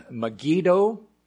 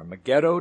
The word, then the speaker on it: Megiddo: So we are going to go to Mount Carmel and then Megiddo, or Megiddo, depending on how you like to pronounce that, then the Jezreel Valley.